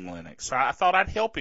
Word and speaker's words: Linux: But Linus Torvalds pronounces Linux, Linux. Let's hear that again. – Hello, this is Linus Torvalds, and I pronounce Linux as Linux.